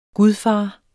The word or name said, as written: gudfars